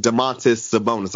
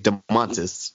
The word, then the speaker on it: Domantas Sabonis: It was Bam Adebayo and Domantas Sabonis. I learned how to say it. – Domantas.